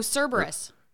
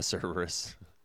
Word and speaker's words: Cerberus: If you look in the Thoth deck, the Aleister Crowley deck, on the Hermit card, there's a Uberus. Is that how you say it? The three-headed dog? – Oh, Cerberus. – Oh, yeah, Cerberus.